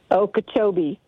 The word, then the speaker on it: Okeechobee: Okeechobee.